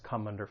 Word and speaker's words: come under: That final consonant sound in come is going to get linked with the vowel sound in under. Come under. Come under fire.